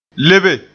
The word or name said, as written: lebe